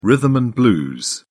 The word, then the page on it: rhythm and blues: British English